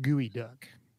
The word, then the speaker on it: geoduck: He says geoduck is pronounced gooey duck.